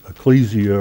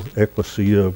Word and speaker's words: ecclesia: It comes from the Greek word ecclesia or ekklesia, whichever way you want to pronounce it.